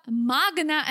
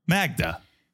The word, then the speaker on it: Magda: This moron, and I like Joe, but this moron needs to learn how to pronounce his girlfriend's name. Like, seriously, Magda is not a difficult name. I'll give you that. Magda is not a difficult name. There's no N in that name, Joe.